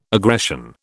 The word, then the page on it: aggression: British English